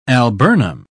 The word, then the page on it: alburnum: British English